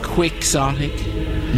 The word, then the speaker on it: quixotic: Quixotic. – You have to say the X then, right? – Well, that's because the English don't like to pronounce foreign phrases the way foreigners do.